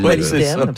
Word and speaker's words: molybdène: Déjà, comment bien le prononcer aussi. – Le molybdène, c'est ça. Bravo.